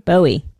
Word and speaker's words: Bowie: This is by this young up-and-coming artist by the name of David Bow Wow? How do you say it? What? – Who Bowie, is? Dude. Bowie.